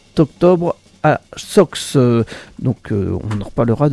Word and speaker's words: Sox: C'est à la salle municipale de Sox. Sox, ça s'écrit S-O-X c'est X, bien sûr.